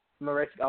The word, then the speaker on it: Mariska: Am I saying her name wrong? Please. – Mariska.